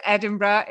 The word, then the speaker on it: Edinburgh: It's Edinburgh in Scotland, in the UK.